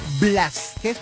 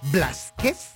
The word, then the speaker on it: Blázquez: María Fernanda Blázquez, porque tiene el acento en la A. Blázquez Gil.